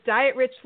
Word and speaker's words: Dietrich: Welcome to today's episode of the Diet Rich Life. I'm your host, Heidi Dietrich. And yes, that's spelled like Diet Rich.